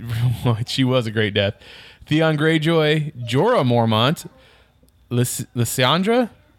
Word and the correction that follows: Melisandre: I know I never say the name right. – Melisandre, thank you.